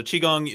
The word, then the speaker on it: Qigong: But yeah, so Qigong, you probably have a hard time pronouncing it because it's spelled a Q. It's spelled Q-I-G-O-N-G. The Qi, Q-I, means like the energy in your body. The Gong means a skill at working with that energy.